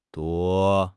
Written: duo1

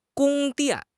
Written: 宫殿

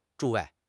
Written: 诸位

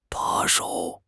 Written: 把手

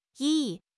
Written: 翼

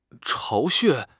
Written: 巢穴

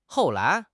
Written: hou4 lai2

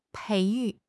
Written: 培育

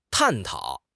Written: 探讨

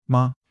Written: ma5

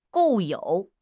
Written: 固有

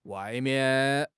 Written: wai4 mian4

